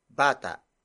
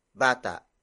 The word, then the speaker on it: bata: The next word is ang, which serves as the focus marker for its next word, bata. Bata.